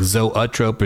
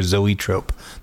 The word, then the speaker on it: zoetrope: I've got plenty of, of, of audio pronunciations of zoetrope, which is like zoetrope or zoetrope. Those are the two ways you can say it.